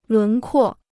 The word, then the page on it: 轮廓: lún kuò